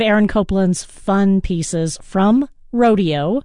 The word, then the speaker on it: Rodeo: Rodeo, or Rodeo, as it's sometimes pronounced.